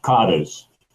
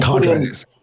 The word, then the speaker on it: cadres: Transnet is quite an interesting example of what happens when government decides to get rid of the cadres. Is that the right way you pronounce it? – And cadres. – Cadres, yeah.